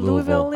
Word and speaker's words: Louisville: How do you say Louisville in Louisville? – I just say Louisville.